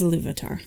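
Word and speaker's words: Ilúvatar: So we start off right off the bat with Eru, the one who in Arda is called the Iluvatar. Am I pronouncing that right, guys? How did you do it in your heads? – I believe it is Iluvatar. – Yep. At least it is now.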